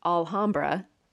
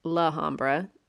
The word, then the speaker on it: Alhambra: His house is in Alhambra, not Lahambra. – Right. – There's an A there.